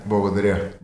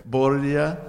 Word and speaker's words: Благодаря: Благодаря. – Благодаря.